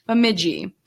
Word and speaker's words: Bemidji: So this week we're actually going to be in Minnesota, and we're in Bemidji, and I did listen to the pronunciation several times to make sure I said that right. So Bemidji, we are going to be talking about the disappearance of Nevaeh Kingbird.